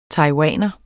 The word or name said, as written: taiwaner